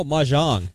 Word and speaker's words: Mahjong: Oh, Mahjong.